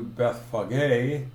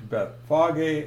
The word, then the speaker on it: Bethphage: If we look at Mark 11, 1 and 2, now when they drew near Bethlehem to Bethphage, and that's proper pronunciation, most people say Bethphage or something like that, or Bethphage. Bethphage is correct,